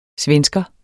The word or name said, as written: svensker